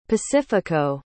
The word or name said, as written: pacifico